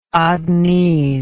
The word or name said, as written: adenese